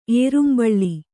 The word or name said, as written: ಏರುಂಬಳ್ಳಿ